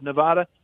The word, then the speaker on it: Nevada: Porter, represented the 3rd Congressional District of Nevada.